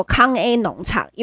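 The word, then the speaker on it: 康 A 农 场 (空ㄟ農場): 就是 b p m f 注音的那个 A，所以它的名字就叫做康 A 农场，因为它的意思就是用台语来发音。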